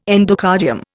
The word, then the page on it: endocardium: Indian